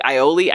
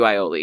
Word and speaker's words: aioli: If you tell me, like, oh, like, aioli, I can't do aioli, because I just, even if it's with Miracle Whip, I'm just, like, I know, I can't.